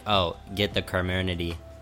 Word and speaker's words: Comirnaty: And apparently the word is spelled Comirnaty. And apparently it's pronounced Comernity or something.